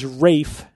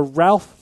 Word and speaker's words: Ralph: But the old English pronunciation is Rafe, so his name is Rafe Fiennes, which is interesting because I always either thought he was Ray Fiennes or Ralph Fiennes.